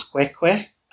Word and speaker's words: Khoikhoi: And the main inhabitants were the Khoikhoi, which some people pronounce Kwekwe, and they were also groups called the Sun or the Bushmen.